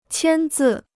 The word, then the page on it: 签字: qiān zì